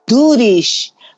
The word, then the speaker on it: Doorish: O sea, su nombre se escribe D de dedo, O, O, R de rata, y S, H, Doorish, que él nos acaba de explicar que es Durish en, irlandés o en inglés.